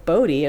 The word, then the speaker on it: Bodie: I saw Bodie, and I'm like, that looks like it's called Bodie. And I looked on Wikipedia, and sure enough, that's how to pronounce it.